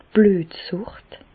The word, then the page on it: d' Blüetsùcht: Bas-Rhin; Reichshoffen